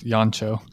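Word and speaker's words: Jancsó: By the way, it's in my research, I believe it's pronounced Yoncho, as in rhyming with poncho.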